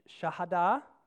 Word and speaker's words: Shahada: Or in Islam, if you practice the five pillars of Islam, which are, you know, the Shahada, and I don't always say that right, Shahada,